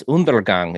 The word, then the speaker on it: Undergang: It's not really that easy, I guess, for people not speaking the Danish thing, which just pronounces like it's two N's, so it's undergang.